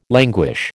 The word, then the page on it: languish: American English